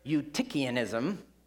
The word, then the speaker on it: Eutychianism: These are big words like adoptionism, or Apollinarianism, or Arianism, or Docetism, or modalism, or my personal favorite, Eutychianism. And you would never know that's how you say it by having it spelled out.